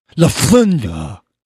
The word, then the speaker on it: Lufthansa: Lufthansa. – Well, try not to fly over. – Is that how they pronounce it? – Yes. That's how they do it in Germany.